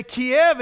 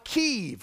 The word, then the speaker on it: Kyiv: But we can't even say Kiev anymore because that was the Russian pronunciation. And that's what it was called for so many years. And that was the birthplace of the modern Soviet movement. Now we got to call it Kyiv, because that's the Ukrainian pronunciation.